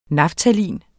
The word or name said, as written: naftalin